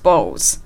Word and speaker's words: bowls: さて、ということで、ここまでで、 bowl, these bowls というのが何なのかということが分かっていただけたかと思います。すごく難しかったんですが、発音もですね、スポーツに使う bowl と違いまして、小さいうを入れるところがポイントなんですね。